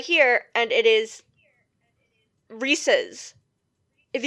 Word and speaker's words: Reese's: Since Reese's is pronounced after the man himself, and they're his peanut butter cups, the correct pronunciation is in fact Reese's instead of Reese's.